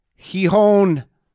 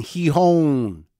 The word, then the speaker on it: Gijón: G-I-J-O-N. Gijón. Is that right? Gijón. Feels right to me. I just learned that today after saying Gijón for a while and Gijón or Gijón.